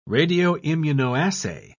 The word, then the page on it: radioimmunoassay: British English